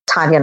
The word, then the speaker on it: Taniya: So everything on every social media, whether it's Facebook or Twitter or Instagram or my website, it's just Taniya Nayak is one word.